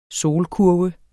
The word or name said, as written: solkurve